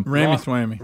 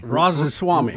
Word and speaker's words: Vivek Ramaswamy: They're having on, and we got to learn how to say this guy's name, Bubby. He's picking up momentum. – Ramaswamy. – Ramaswamy. – Vivek. – You know, you'll say this. – Vivek Ramaswamy. – That's it.